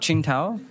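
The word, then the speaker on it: Qingdao: It's called Qingtao.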